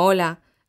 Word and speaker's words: hola: Oye, ¿cómo se pronuncia hola? – Como hola, la letra H no se pronuncia en español.